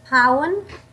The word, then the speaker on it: Paun: Paun.